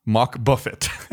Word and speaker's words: Mach buffet: Wait, the Mach bucket? – Mach buffet. So it's spelled like buffet, like buffet.